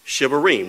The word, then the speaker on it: shevarim: Shivarim, which is spelled S-H-E-V-A-R-I-M.